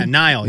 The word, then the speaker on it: Niall: And how do you pronounce the guy's name who's from One Direction that's going to be in there, Too Slim? Is it Niall? Is that his first name? – Oh, yeah, Niall, yes.